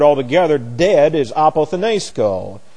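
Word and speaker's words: apothenesko (ἀποθνῄσκω): And the accent goes here, and then we have S-K-O, long O on the end. This is A-P-O-T-H-N-E-S-K-O, apothenesko.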